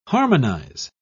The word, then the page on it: harmonise: British English